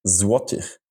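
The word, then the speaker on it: złoty: Und wie spricht man das richtig aus? – Zloty.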